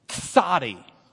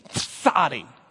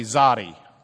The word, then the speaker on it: tsadi: Pronounce the T and the Z. Tsadi. Come on. Tsadi. We actually have that in an English word. Sort of an English word. The tsar. Do you ever wonder why the tsar starts with a ts? Because it's the tsadi. Okay? So you pronounce it like the tsar. If you're from Lubbock, you can just say czar. And you can just say zadi.